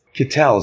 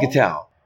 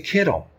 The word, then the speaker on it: Kitell: I pronounce it Kitell. That's my family and the rest of the world pronounces it Kittle.